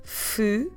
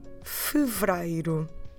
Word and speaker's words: fevereiro: We don't actually say it that way because it's a bit hard to go fevereiro. So what we normally do is that we put the middle syllables together, the second and the third together, and we get the word fevereiro. Okay? So we reduce these four initial syllables to only three when we pronounce it.